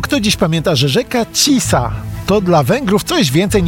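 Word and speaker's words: Tisza: Po węgiersku Tisa, nazwa pisana przez SZ, które wymawia się jako S.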